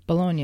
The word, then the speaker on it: Bologna: Bologna, yeah.